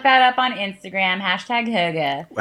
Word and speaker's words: hygge: H-Y-G-G-E. So you want to pronounce it hygge. – Which I would have spelled H-U-G-U. – No. – With maybe an umlaut in there. – It's hygge.